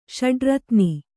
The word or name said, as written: ಷಡ್ರತ್ನಿ